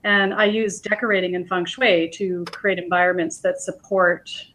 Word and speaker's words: feng shui: Feng shui? Am I saying that right? – Yeah, it's feng shui is what feng shui. It is. – So, I was right.